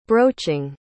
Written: broaching

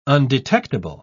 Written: undetectable

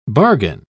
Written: bargain

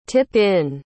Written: tip-in

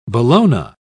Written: bologna

